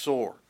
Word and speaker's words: Tyre: Ezekiel 27 as well as 26 describes the city of Tyre, the way that we would pronounce it. Actually, probably in the Hebrew, it's pronounced Sor.